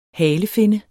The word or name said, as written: halefinne